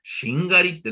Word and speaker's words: 殿: まあ、ここでね、日本語はとても難しい言葉、しんがりってね、なかなか使わないし聞かないんですが、これはね、兵法、ね、戦いの方法の言葉なんですね。兵法で、しんがりってね、漢字で書くとね、殿様の殿って書くそうなんですね。自分も初めて知った。殿様の殿と書いて、しんがりと読むんですが、この人たち何をする人たちなのか、それは戦争、争い、戦をしている中で、この争いはもうね、勝ち目がない。